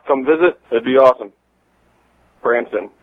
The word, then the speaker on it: Branson: And we mentioned when we were talking about we should do the next bypass in Branson, Missouri, and this is what he had to say about that. – Also, it's Branson, Missouri, not Bronson. Don't pronounce it like that, because that's weird. It's Branson, and yes, let's drive down there.